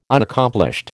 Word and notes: Unaccomplished: American English

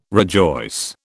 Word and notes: rejoice: British English

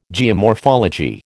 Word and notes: geomorphology: American English

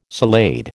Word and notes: salade: American English

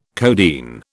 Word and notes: codeine: British English

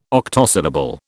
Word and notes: octosyllable: British English

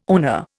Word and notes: oner: Indian

